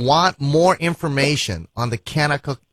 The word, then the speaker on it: Kanakuk: Kanakuk is spelled K-A-N-A-K-U-K.